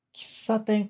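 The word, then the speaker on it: Kissaten (喫茶店): Kissaten começa com K-I, mas a gente mal pronuncia esse I, tá? A gente pronuncia praticamente só o K.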